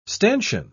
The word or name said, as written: stanchion